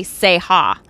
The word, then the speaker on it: Ceja: You're a comedian, and my last name is literally Ceja. – Ceja. – That's how you pronounce it, yeah.